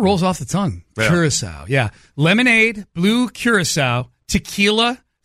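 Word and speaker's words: Curaçao: Is it C-U-R-A-C-A-O? – Curacao. – Curacao, okay.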